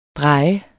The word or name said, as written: DREJEDES